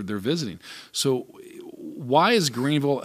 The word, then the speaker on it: Greenville: So when I'm reading and traveling, I know when I would fly into town, it was always called Greensville. They would always mispronounce it. – You notice that too. – And now it's always Greenville.